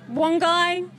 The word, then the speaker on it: Wangi: Well, now, my pronunciation is not necessarily correct, but Wangi, Wangi. – Look, Wangi, Wongai. Wongai. – Wongai Falls.